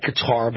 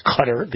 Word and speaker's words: Qatar: It looks like Qatar, but it's pronounced Qatar, the nation of Qatar.